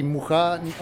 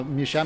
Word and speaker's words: Mucha: Euh, en Prague, on dit Mucha, on, ne dit pas Micha, mais ici, il a utilisé cette prononciation.